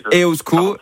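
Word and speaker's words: Eusko: Non, mais vous faites bien déjà, Iban, vous dites EOSCO, donc on va déjà le prononcer correctement, ça c'est déjà moins de...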